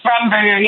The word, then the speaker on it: Rumberger: Rumberger, yeah.